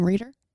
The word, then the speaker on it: Reider: That's how you'd pronounce the last name, Reeder. – Reeder? Reeder. And we'll ask her. – R-E-I-D-E-R.